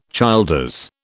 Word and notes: Childers: British English

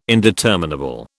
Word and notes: indeterminable: British English

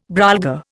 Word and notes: brolga: Indian

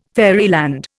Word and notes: fairyland: Indian

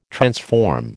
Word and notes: transform: American English

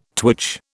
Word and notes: twitch: British English

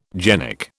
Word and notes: genic: British English

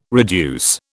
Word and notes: reduce: British English

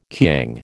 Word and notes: kiang: American English

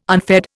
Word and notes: unfed: Indian

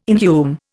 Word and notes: inhume: Indian

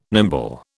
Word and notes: nimble: British English

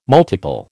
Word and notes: multiple: American English

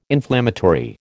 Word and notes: inflammatory: American English

